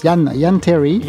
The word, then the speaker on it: Jan Terry: Donc on peut dire Jan, hein, puisqu'elle est américaine, donc c'est J-A-N, donc ça se prononce Jan, Jan Terry.